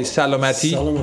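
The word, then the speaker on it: salamati (سلامتی): Okay, so in Farsi, when we cheers, we say salamati. – Salamati. Am I saying it right? – Yeah.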